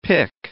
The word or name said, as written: pick